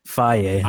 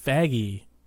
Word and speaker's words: Feige: Faye, like the Greek yogurt. – I was calling him Faggy, but I never said that to anyone because I didn't know if that was right or not.